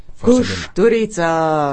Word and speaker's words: Kusturica: Sei il grande Emir Kusturica, che non è Kusturica, non è Kusturica, non è Kusturica, Emir Kusturica, è un po'... è difficile, è un accento difficile.